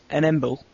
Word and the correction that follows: Ensemble: An Ensemble.